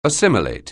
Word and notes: assimilate: anglais

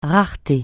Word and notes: rareté: francais

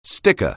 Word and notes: sticker: anglais

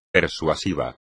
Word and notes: persuasivo: espagnol

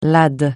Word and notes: lad: francais

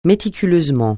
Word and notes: méticuleusement: francais

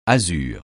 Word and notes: azur: francais

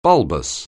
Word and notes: bulbous: anglais

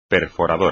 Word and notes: perforadora: espagnol

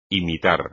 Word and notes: imitar: espagnol

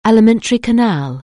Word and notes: alimentary canal: English

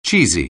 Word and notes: cheesy: English

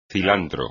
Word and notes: cilantro: espagnol

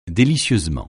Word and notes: délicieusement: français

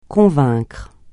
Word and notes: convaincre: francais

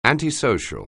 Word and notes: antisocial: anglais

